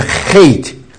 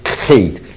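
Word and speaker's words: Het: 然后下面黑是一个重音的像德文的哇 ch 的发音，像德文 ch 的发音啊，哇啊，但是它这个读音名是 hate 很重的喉咙重音 hate 啊，hate 这个 t 是等于英文的 t 的发音。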